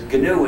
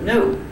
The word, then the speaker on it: GNU: The idea that that G is supposed to be silent is so silly that we just have to make jokes up about pronouncing it as gnu instead of new. However, when it's the name of our operating system, please do not follow the dictionary.